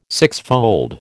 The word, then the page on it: sixfold: American English